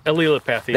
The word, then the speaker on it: allelopathy: So I can never remember exactly how to pronounce it. Allelopathy? – Allelopathy. – That's it. Yeah.